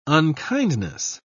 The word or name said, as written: unkindness